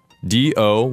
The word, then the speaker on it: どう: D-O with a long O, do.